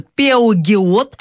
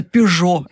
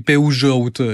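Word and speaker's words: Peugeot: По поводу русского языка, сложный язык, нормально по-французски пишутся «Пеогиот», а читается «Пежо». Это какой язык еще сложный? – Ну, это фамилия, да-да-да. Я помню, да, даже по-испански они «Пеужоут» скажут.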